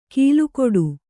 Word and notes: ಕೀಲುಕೊಡು: kīlu koḍu

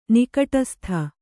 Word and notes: ನಿಕಟಸ್ಥ: kannada

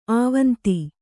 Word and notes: ಆವಂತಿ: āvanti

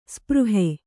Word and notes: ಸ್ಪೃಹೆ: spřhe

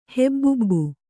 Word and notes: ಹೆಬ್ಬುಬ್ಬು: kannada

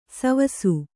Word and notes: ಸೇವಿಸು: Kannada

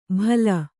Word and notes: ಭಲ: Kannada

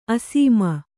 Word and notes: ಅಸ್ಸಾಮಿ: assāmi; Kannada

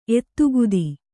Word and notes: ಎದೆಗತ್ತಿ: edegatti

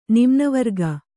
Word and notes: ನಿಮ್ನವರ್ಗ: Kannada

